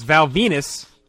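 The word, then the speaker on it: Val Venis: And that's Val Venus, Mr. Rhymes with Penis,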